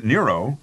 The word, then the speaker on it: Nero: Some people thought the name Nero had a good, ancient ring to it, and noted that Nero rhymes with hero. Nero also rhymes with zero,